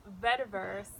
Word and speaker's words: vetiver: It's vetiver.